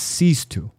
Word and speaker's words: Sixto: So, just so you know, I'd been pronouncing his name wrong. It's Sistu. – Sistu. – It's spelled 6-2. – Yeah, yeah. – But pronounced Sistu.